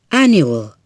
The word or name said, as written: annual